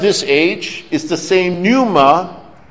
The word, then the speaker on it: pneuma: Or some people call pneuma. It's actually pneuma with a silent P.